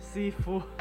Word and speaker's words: Sifu: ¿Cómo es Sifu? – Sifu. – ¿Cómo se escribe? – Así, S-I-F-U.